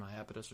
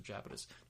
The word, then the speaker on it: Iapetus: one of the moons of Saturn, Iapetus or Japetus, depending on how you want to pronounce it, with the I or the J, Latin, blah, blah, blah.